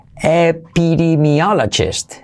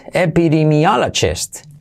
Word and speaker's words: epidemiologist: So, in English es epidemiologist, epidemiologist, epidemi, eso sí que son is, epidemi Epidemiologist. Letra clave GJ, epidemiologist, alright?